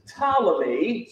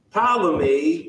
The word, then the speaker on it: Ptolemy: So the king of the south is Ptolemy, spelled with a P, Ptolemy the First.